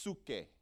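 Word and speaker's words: suke (ψυχή): Like how we would spell psyche, but it's, it's pronounced suke, right?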